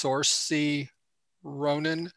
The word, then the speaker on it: Saoirse Ronan: and I think she said her name is pronounced Saoirse Ronan.